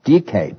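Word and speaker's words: dike (δίκη): In fact, the ancient Greek word for justice, dike, D-I-K, the long E, originally referred to a bifurcating path.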